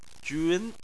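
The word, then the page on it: jun: tone 2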